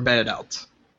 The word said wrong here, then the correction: bidet: Bidet. – Oh, is that how you say it? – Yeah, it's a bidet.